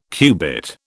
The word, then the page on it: cubit: British English